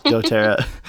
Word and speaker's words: doTERRA: doTERRA? – Yes. Okay. doTERRA products have um, enhanced your life, I guess, and, and been something that's been really beneficial for you.